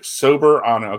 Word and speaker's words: Soberano: I said this wrong earlier, Sobernero Jr.? – Soberano. – Soberano. Yeah. Sober, like as in sober as in I don't drink. Soberano. Okay, I got it now.